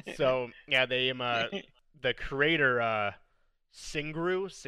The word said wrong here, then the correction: Shigeru: is that how you say it? – Shigeru Miyamoto. – Yeah, there you go. Shigeru, yeah, exactly what he said.